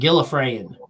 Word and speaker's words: Gallifreyan: Gallifreyan.